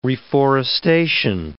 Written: reforestation

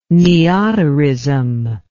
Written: neoterism

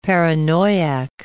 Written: paranoiac

